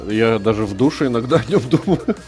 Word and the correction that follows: в душе: Ой, неправильное ударение, ну в душе, в душе.